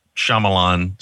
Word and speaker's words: Shyamalan: Shyamalan, is that how you say his name? – Yep.